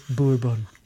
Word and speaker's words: Bourbon: And then he says, Noah, pronounce it Bourbon. Bourbon.